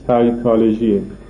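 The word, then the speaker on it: psychology: تلفظی که ما می‌کنیم میگیم فیزیکولوژی، تلفظ فرانسویشه. تلفظ انگلیسی سایکولوژیه. پیش تلفظ نمیشه و بعدش هم سعی تلفظ میشه.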